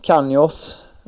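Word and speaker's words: Canós: Och han, unge spanjoren Brentford där, Canjos... slaktade säkert hans uttal där på hans efternamn där, men helt rätt.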